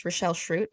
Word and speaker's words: Rachelle Shrute: Well, as you properly pronounced, my name is Rachelle Shrute. I've been called Rachel a lot on podcasts.